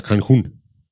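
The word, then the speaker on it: Cancún: Quintana Roo, Cozumel, Cancun. Oder Cancun, wie der Mexikaner sagt.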